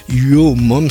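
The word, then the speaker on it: URMU: Uomom, ça s'écrit U-R-M-U c'est Your Mom, ta mère quoi.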